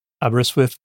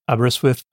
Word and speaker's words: Aberystwyth: Aberystwyth, Aberystwyth, Aberystwyth.